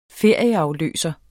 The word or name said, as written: ferieafløser